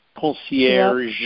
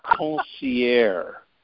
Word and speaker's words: concierge: You know how there's a French word called concierge, C-O-N-C-I-E-R-G-E, concierge. – Yep. – So some people pronounce it concierge. And I go, wait a minute, wait, where's the je? Where's the je? Where's the G-E? Concierge.